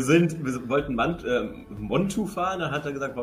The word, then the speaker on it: Montu: Äh, ich habe den Namen endlich richtig ausgesprochen. Montu ist ein cooler Invert. Ähm, hey, was hast du denn sonst gesagt? – ich habe ich hab immer Manta oder also,